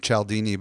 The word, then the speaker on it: Cialdini: Well, and for those that are interested, I pronounce his last name Cialdini, but Robert Cialdini's book is called Influence, Science, and Practice, and it's such a great book.